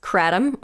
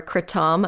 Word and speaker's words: kratom: Yeah, well, a legal one that I really like is kratom or kratom. I've heard people pronounce it both ways.